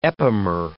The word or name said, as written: epimer